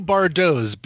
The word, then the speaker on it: Bardots: This is a band called The New Bardots, B-A-R-D-O-T-S. That T is silent, but they're not.